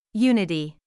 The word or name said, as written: unity